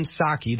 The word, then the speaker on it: Psaki: Her name is Jen Psaki. The P is silent.